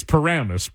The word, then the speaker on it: Paramus: But right, it's Paramus. But I could see them putting the accent on the wrong syllable.